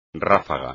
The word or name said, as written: ráfaga